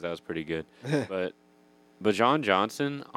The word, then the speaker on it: Bijan: Bijan.